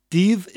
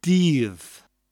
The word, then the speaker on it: Dydd: Div is spelled D Y D D and means day. Div. Almost sounds like the word deed with a double D sound, a th sound at the end.